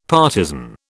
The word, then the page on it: partizan: British English